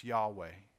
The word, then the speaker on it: Yahweh: And when you take that verb and turn it into a noun, it's pronounced Yahweh.